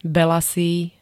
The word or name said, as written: belasý